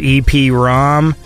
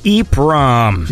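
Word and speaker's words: EEPROM: and I said it was EP ROM, and apparently it's pronounced EEP ROM.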